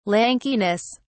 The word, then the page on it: lankiness: British English